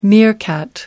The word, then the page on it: meerkat: American English